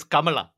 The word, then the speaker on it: Kamala: And also, wait, I'm pretty sure it's Kamala Khan. I think that's how they pronounce the name in the comics.